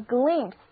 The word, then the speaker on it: glimpse: 同样表示一瞥，瞥见的还有一个单词叫做 glimpse， glimpse，字母 i 发的音是短的 e，结尾的字母 e 不发音，一起来读两遍吧， glimpse， glimpse， glimpse， gl 是词根表示光，而 impse 的意思是无力的，那眼睛无力的闪了一下，就是我们常说的眼皮都懒得抬一下的看了一眼，那不就是一瞥吗？